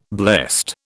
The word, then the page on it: blessed: British English